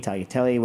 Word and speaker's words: tagliatelle: tagliatelle, whatever you want.